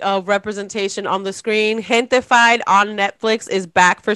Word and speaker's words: Gentified: Can you say it again? That's how it's said? – Gentified. – Oh, I thought it was gentrified.